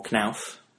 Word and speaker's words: Knauf: He now heads public affairs across Europe for Knauf. I hope I'm pronouncing that right. – It is perfect, yes.